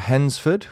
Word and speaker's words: Hednesford: Here are five quickfire facts about Hensford, which is spelled H-E-D-N-E-S-F-O-R-D. There's a lot of silent letters in Hensford.